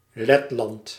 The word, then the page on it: Latvia: British English; /ˈlæt.vi.ə/; /ˈlɑːt.vi.ə/; American English